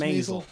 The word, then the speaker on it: Maisel: Did we decide it was Maisel? Rhymes with nasal. – She says Maisel.